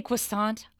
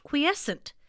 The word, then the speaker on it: quiescent: Did you say croissant? I am so hungry. – No, it's pronounced quiescent.